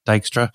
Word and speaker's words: Dijkstra: Dijkstra, is that how I pronounce it? You corrected me earlier. Dijkstra, yeah. People like look at a problem and go, oh, this Dijkstra algorithm.